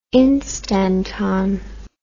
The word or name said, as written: instanton